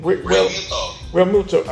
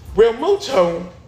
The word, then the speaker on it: Realmuto: How do you pronounce this man's name? – Realmuto. – Okay. Thank you. Realmuto, yeah, he gonna score in that run.